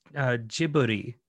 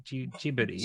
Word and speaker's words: Ghibli: It's Ghibli. – So we looked up, we've got the distribution history of Studio Ghibli. No, I'm saying it wrong. – I think it might even be Ghibli. Ghibli.